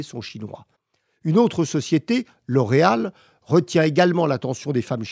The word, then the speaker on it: L'Oréal: L'Oréal, prononcé Oulaya en chinois, signifie la beauté qui vient d'Europe.